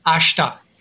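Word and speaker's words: ashita: Or you could just say, like, if you're going to see someone the next day, you could just say mata, which is M-A-T-A, which means again, and then ashta, A-S-H, silent I-T-A, mata ashta.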